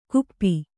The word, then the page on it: ಕುಪ್ಪಿ: kuppi